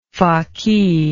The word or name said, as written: faqih